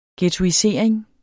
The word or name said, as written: ghettoisering